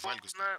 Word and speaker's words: вальгусная: Куда ставим ударение? Вальгусная стопа или вальгусная? – Ровно на первый слог. Вальгусная стопа.